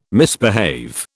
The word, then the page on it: misbehave: British English